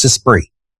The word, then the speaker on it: Esprit: I said, Jody, how do you pronounce that, the name of that brand that you used to wear when you were a teenager? Is it Esprit? And she goes, why, you see it at Costco? I said, yeah, that's what we're talking about. And I want to make sure I'm pronouncing it correctly. She goes, yeah, it's Esprit.